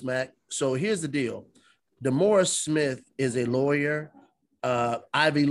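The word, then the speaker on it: Demaurice: Damaris. His name is Damaris. Okay. It's spelled D. It's spelled Maurice. Like D. Maurice. – Yeah. Spelled like D. Maurice. But he called him Damaris.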